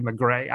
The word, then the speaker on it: Maigret: I should pronounce it correctly, McGray. I always say it with the hard T because I'm an American who loves to bungle things.